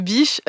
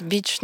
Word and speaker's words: Biche: J'imaginais qu'il y avait une prononciation à l'anglaise, beach, non pas du tout.